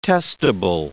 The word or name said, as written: testable